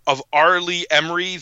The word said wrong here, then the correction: Ermey: the Ermy, R. Lee Ermey, excuse me, I always get his name wrong the first time I say it, uh, the legendary drill instructor from Full Metal Jacket as Wildcat, mm-hmm.